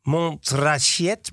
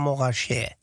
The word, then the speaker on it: Montrachet: Het is niet Montrachet, maar Morachet.